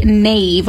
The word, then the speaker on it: knave: Or what about a knave with a silent K in there? – Knave, that's somebody, I should know this one.